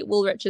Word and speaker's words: Woolrych: So on Instagram, I am Charlotte Woolrich underscore photography. Woolrich is spelt WOOLRYCH, and Charlotte Woolrich underscore cosplays.